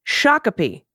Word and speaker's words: Shakopee: Shakopee Shakopee.